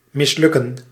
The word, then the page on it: rater: France; [ʁa.te]